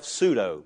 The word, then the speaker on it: pseudo: That's a funny word because the P is silent. I always wondered, if a letter is silent, why put it there to begin with? You ever thought that yourself? Pseudo. P-S-U-E-D-O.